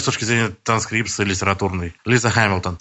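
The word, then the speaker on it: Hamilton: Но, в принципе, наверное, все-таки, хотя принято говорить «Гамильтон» и город там в Канаде, который также называется тоже, но все-таки, наверное, правильно произносить «Хэмилтон».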